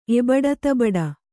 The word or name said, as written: ಎಬಡತಬಡ